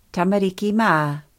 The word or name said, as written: tamariki ma